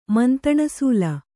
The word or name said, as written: ಮಂತಣಸಾಲೆ